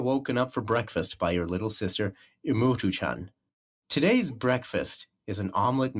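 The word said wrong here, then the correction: imouto: Is that how you pronounce that enigma? – It's Imoto. – Imoto.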